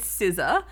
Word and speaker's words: SZA: Anyway, it's SZA, but it's spelled SZA.